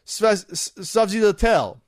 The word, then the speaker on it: sfogliatelle: You have a sfogliatelle, which is hard to pronounce because it's spelled S-F-O-G-L-I-A-T-E-L-L-E. Sfogliatelle. That's kind of how you say it. We always call it sfogliatelle because it's easy to say that.